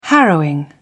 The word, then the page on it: harrowing: British English